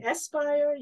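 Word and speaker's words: Speier: You can find me on Facebook at Dr. Diane Espire.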